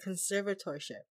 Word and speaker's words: conservatorship: I don't know. I just kind of like, you know, run through it as Californians do and don't actually pronounce any of the letters. Conservatorship. – How they say we don't, Californians don't pronounce the T's. – See? Conservatorship. Yep. See? There's no T.